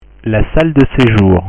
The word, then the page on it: la salle de sejour: French